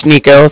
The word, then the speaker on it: Niko: Okay, so like I said, my name's Nico. That's spelled N-I-K-O. And then the Bank of Cardiff is spelled C-A-R-D-I-F-F, as in Frank Frank.